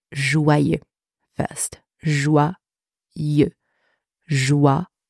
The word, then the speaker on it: joyeux: For English speakers, seeing the letters J-O-Y together, there is a tendency to want to pronounce this letter combination as joy. Right? Fair enough. But in that word, the letters J-O-Y together are pronounced joie, joie, with wa, wa. like W-A, literally, wa, wa, joie. Then we have the letters Y-E-U.